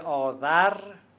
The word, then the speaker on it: آذر: کلمه آذر با زال نوشته میشه اما با ز تلفظ میشه.